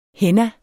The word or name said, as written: AD